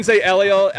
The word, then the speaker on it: Eliel: Yeah, you can say Eliel. Actually, it's an old Hebrew name, and it's Ryan, my partner's middle name.